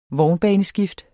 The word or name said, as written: vognbaneskift